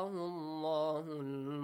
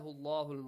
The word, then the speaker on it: Allah: Anata Allahul Mulk. Atahu Allahu. The word, the letter lam in the word Allah is pronounced heavily, right? Allah.